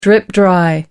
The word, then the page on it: drip dry: American English